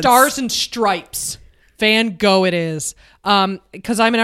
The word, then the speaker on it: Van Gogh: Van Gogh is, if I was to pronounce it correctly, in Dutch it would be Van Gogh, right? And if it was in French, I would say Van Gogh.